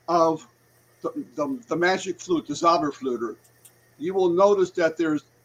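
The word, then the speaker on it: Zauberflöte: If you remember, Mozart was a Mason, and Mozart in his last opera called the Magic Flute, which was the Zauberflüter, which that's how you say it in German, the Zauberflüter.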